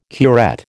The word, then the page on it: curette: American English